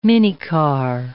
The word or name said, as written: minicar